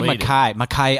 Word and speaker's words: Mackay: They say Mackay. I'm of the Mackay clan. – Yeah, there you go. – Oh, is that how you say it? – That's how you say it in Scotland.